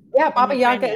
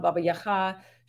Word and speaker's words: Baba Yaga: Baba Yaga is what we say in English, because that's really the Russian pronunciation, but that's the pronunciation that was sort of spread all over. So people will know her as Baba Yaga, or Baba Yaga, but in Ukrainian it's Baba Yaga. It's just a different sort of sound to that second syllable of Yaga or Yaga.